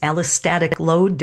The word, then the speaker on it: allostatic load: And you taught me that new phrase, allostatic load. Did I pronounce that correctly? – Yes, yes, you did.